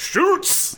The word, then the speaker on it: Schulz: Schulz? I don't think I'm saying that right. – I'm supposed to say Schulz!